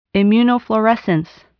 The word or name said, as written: immunofluorescence